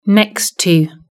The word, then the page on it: next to: British English